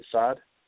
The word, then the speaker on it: Saad: Saad? – Yep.